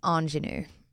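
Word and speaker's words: ingénue: Good pronunciation of that word. – I looked at it and I thought, is it going to come out right the first time? – Ingenue. I think you were bang on.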